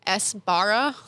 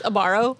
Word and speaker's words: Sbarro: Which I didn't know how to pronounce until last year. I thought it was Sbarro. Because I was getting too into the letters. And Sbarro. – And then I thought it was like Sbarro. Like it, just like it's a very subtle S where it's just not there. It's almost like an apostrophe. It's like just Sbarro. – It's Sbarro. – Yeah. – Silent S.